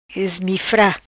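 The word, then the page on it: Ismifra: Patuá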